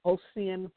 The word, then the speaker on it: Ossian: Henry Ossian Flipper, and I think I'm saying his name right. It could be pronounced Ossian because it's O-S-S-I-A-N. But I'll call him Ossian, Henry Ossian Flipper.